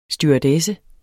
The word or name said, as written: stewardesse